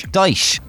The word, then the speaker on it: Dyche: Hardman football boss Sean Dyche, is it, how do you pronounce his name, the Burnley boss, Dyche.